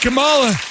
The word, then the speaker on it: Kamala: Yeah, go get Kamala.